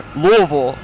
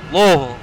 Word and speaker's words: Louisville: Yeah, but it's like Worcester. Worcester. – Worcester. – Worcester. It's like Worcester, but we say Louisville. – Louisville.